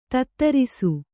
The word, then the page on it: ಧಾತುರಸ: dhātu rasa